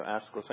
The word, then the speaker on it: Aeschylus: So Aeschylus. There you go, Aeschylus.